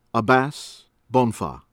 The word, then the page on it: Abass Bonfoh: American English